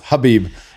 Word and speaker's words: Khabib: Speaking of Joey Diaz, he calls him Khabib, which is great. It's pronounced Habib.